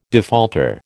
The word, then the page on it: defaulter: American English